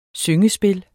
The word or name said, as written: syngespil